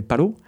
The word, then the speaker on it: Paro: Je pense par exemple à ce bébé phoque robotisé qui s'appelle Palo, donc P-A-R-O, mais comme le R ne se prononce pas en japonais, on va dire Palo.